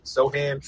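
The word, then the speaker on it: Sochan: How do you pronounce it? Sochin or so Sohan? Sohan, but it, so- it, that, I, I honestly didn't know how to pronounce it until tonight.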